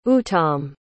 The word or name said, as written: uttam